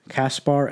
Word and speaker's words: Caspar: I'm going to butcher this. Melchior, Caspar, and Balthazar. – Actually, you nailed it.